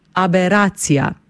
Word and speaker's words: aberacija: Gre za samostalnik ženskega spola, besedo sestavlja 9 črk, od tega 5 samoglasnikov in 4 soglasniki, pri izgovori pa smo pozorni na drugi A, torej nad njim napišemo ostrivec, pravopisno gledano, zato ga pri izgovorjavi tudi poudarimo. Pravilno izgovorjena beseda zveni tako: aberacija.